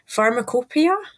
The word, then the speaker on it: Pharmacopoeia: So also in your book, you've got extracts of historical publications, including the Edinburgh, and I apologize if I say this wrong, Pharmacopoeia? – Oh, very well pronounced.